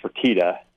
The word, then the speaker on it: Fertitta: It's Fertitta, kind of mentioned the fact that he'd like to see Art Briles maybe get a look.